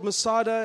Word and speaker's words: Masada: There's a place called Masada. Am I saying it right? Yes, like that.